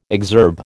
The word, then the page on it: exurb: American English